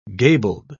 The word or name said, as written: gabled